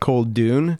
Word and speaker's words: Koldun: It's pronounced Koldun. – Koldun. Yeah, sorry about that. – It's Russian for sorcerer.